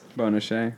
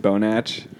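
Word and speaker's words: Bonache: Bona Shea. – Bona Shea. – But it's actually pronounced Bonach. – Yeah, Bonach.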